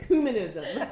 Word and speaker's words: ecumenism: And so Fran goes, put the emphasis on the ooh. And so I really like go for it now. I'm like, ecumenism.